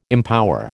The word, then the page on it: empower: American English